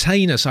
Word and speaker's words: heinous: You know, I've used this word before, heinous. I've used it in an episode of Available back in 2012, and I got the pronunciation right, and I'm inconsistent today with this. I've given you both pronunciations. The heinous. It's heinous.